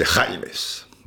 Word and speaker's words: James: Mais en fait c'est Jaimes. Bon, d'accord. Eh, mais pourquoi ça s'écrit James C'est pas...